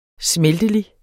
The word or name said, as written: smeltelig